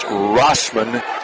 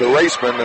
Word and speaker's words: Wrasman: Alyssa Rossman for Fort Loramie, last name spelled W-R-A-S-M-A-N, 5'8 freshman, good-looking young prospect, just a ninth grader, but it is pronounced Rossman, okay. W-R-A-S-M-A-N. – Because I had asked Tom at the game, and he told me it was a long A with a raceman, and that's why I put the line over it.